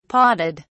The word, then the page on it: podded: British English